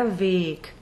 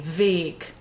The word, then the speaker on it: Weg: This, Another word to pay special attention to in Der Gärtner is Weg. At the start of the second verse, W-E-G, Der Weg. So in this instance, it's a long and closed E, Weg. Now throughout German Lieder and German poetry, you will also see W-E-G written with a lowercase W.